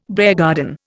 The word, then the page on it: beargarden: Indian